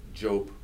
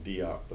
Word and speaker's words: Diop: Uh, Sheikh Anta Job is mispronounced Diop,